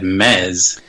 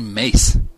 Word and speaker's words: Mes: I'm going to go with Mace, M-E-S, because it's easier to type on Twitter. – I've always said Mez. That's how I pronounce it. – It should be. It's Mesoraco. Why would I say Mace?